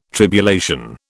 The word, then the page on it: tribulation: British English